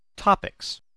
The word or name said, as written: topics